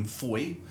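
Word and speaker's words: Fowey: I did tell you, I used to holiday in Foy, which I've pronounced correctly. – Correct. – I've been reliably informed.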